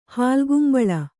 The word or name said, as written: ಹಾಲ್ಗುಂಬಳ